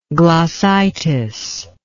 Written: glossitic

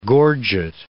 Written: gorget

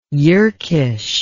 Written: Yerkish

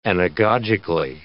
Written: anagogically

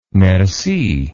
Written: merci

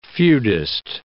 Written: feudist

